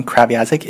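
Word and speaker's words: Kreviazuk: All right, number 98 is Chantel Kravyazik. That's how you pronounce her last name. She's Ukrainian, by the way. She's from Winnipeg, and she has a really, really interesting last name. Kravyazik is how you pronounce it.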